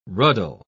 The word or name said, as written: ruddle